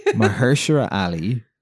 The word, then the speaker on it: Mahershala Ali: Because Mahershala Ali is an actor who's in the third season of True Detective. – You nailed the pronunciation, by the way.